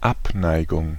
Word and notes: Abneigung: /ˈʔapˌnaɪ̯ɡʊŋ/; /ˈap.ˌnaɪ.ɡʊŋ/

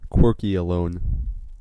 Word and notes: quirkyalone: /ˈkwɜkiələʊn/; /ˈkwɝkiəloʊn/

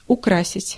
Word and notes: украсить: /ʊˈkrasʲɪtʲ/; ukrasit

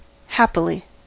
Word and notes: glücklich: /ˈɡlʏk.lɪç/

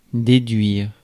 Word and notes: elicit: American English; [ɪˈlɪs.ɪt]

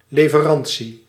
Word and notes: fourniture: France; [fuʁ.ni.tyʁ]

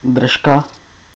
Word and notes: držka: /ˈdr̩ʃka/

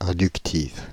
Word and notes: inductives: /ɛ̃.dyk.tiv/; France (Île-de-France)

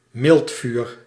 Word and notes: charbon: [ʃaʁ.bɔ̃]

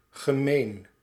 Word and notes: dégoûtant: France; [de.ɡu.tɑ̃]

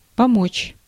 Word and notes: помочь: /pɐˈmot͡ɕ/; pomotš

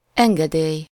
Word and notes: permission: /pɛʁ.mi.sjɔ̃/; France

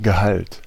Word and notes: Gehalt: [ɡəˈhalt]

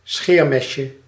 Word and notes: лезвие: IPA: /ˈlʲezvʲɪjə/; lezvije

